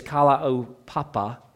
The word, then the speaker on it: Kalaupapa: And details on the history of this colony, known as Kalaupapa, I should have asked Kevin to pronounce this. I think it's Kalaupapa.